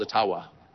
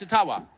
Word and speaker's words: Ottawa: And of course, it was intentional until I received a very sincere email from an Ojibwa First Nations leader who said, actually, it's called Ottawa. And they've been pronouncing it all these years. That, I'm not making that up. And if you have any Ojibwa friends, ask them. It's Ottawa.